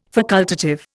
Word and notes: facultative: Indian

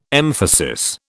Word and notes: emphasis: British English

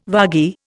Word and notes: vagi: Indian

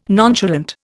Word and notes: nonchalant: Indian

